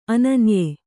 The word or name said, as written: ಅನನ್ಯ